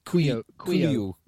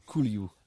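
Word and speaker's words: Couilloud: Yeah, Baptiste Caloud? However you say his name, when he came on, he looked... – Kouliou. – That's an incredible pronunciation. I would never have got there. – Kouliou.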